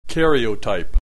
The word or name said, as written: karyotype